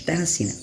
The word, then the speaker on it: Terracina: Cesário, diácono, chegava vindo da África, a Terras, Terracina, eu falei errado lá em cima, gente, Terracina.